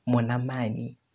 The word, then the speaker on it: mwanamani: It's exactly as I'm pronouncing it now, mwanamani. Mwanamani. I've been saying mwanamani, which is, I mean, if you might not realize it if you don't speak Setswana that my pronunciation was wrong, but if you speak Setswana, you're going to realize just how wrong I was.